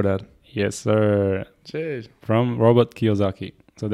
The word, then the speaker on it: Kiyosaki: Robert Kiyosaki. Yeah, Kiyosaki.